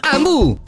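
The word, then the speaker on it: Hambu: Ambu si scrive con l'H.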